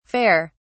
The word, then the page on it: fer: British English